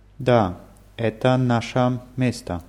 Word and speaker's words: наше: Die Betonung ist auf A. Наше, ваше. Наша, ваша, наши, ваши. Ganz einfach zu merken, weil die Betonung immer auf dem ersten A ist.